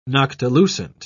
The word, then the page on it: noctilucent: British English